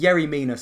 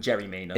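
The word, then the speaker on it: Yerry Mina: I think Yeri Mina sounds better than Jerry Mina. – It's spelt with a Y, but I've heard he pronounces it with a J. – It's spelt with a Y. – It's spelt with a J.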